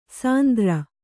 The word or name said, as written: ಸಿಂಧೂರಿ